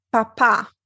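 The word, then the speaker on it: papà: La parola Papa spesso viene confusa dagli studenti con la parola Papa, che ha un accento sulla ultima A.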